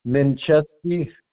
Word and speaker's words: Mencheski: R-A-Y-M-O-N-D, mm-hmm. – Mincheski, M-E-N-C-H-E-S-K-I.